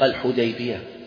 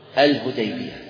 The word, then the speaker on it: الحديبية: توالت العصور على هذه التسمية، فلما كانت في العصور المتأخرة قدم رجل أظن من البلاد الهند أو نحوها، وكان لا يستطيع أن ينطق الحديبية فينطقها الهديبية، فنسي الاسم الصحيح وبقي الاسم المحرف وهو الهديبية.